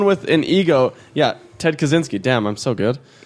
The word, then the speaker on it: Kaczynski: This is all stuff you cut out because I can't pronounce his last name. – Kaczynski. – Thank you.